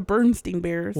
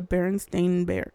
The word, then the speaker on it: Berenstain: Well, it's the Berenstain Bears, not the Bernstein Bears.